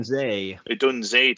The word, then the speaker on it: Odunze: Odunze, to pronounce it.